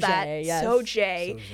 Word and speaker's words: Sauget: So our nominees are the wormy milkshake, the bones falling out, the chloracne, the fact that Sauget, Illinois is called Sauget, and pronounced like how white moms say Target, bankrupting small town farmers for fun, the For the Record Monsanto blog, emaciated horses, mastitis, and John F.